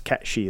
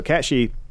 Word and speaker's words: Cat Sith: It's spelled Cat Sith, but it's Cat Shi. That's how it's a Gaelic. Okay. It's a Gaelic mythological character. Anyway, so Cat Shi.